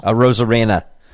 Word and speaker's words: Arozarena: Arozarena.